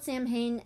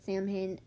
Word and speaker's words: Samhain: it's spelled Samhain, and a lot of people pronounce it Samhain. It's not how it's pronounced. It's pronounced Samhain. – Why? – I don't know, because it's Celtic.